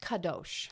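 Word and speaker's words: kadosh (קדוש): So it could be K, it could be Q. But it's pronounced kadosh.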